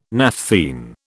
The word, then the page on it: naphthene: British English